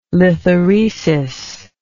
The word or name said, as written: lithuresis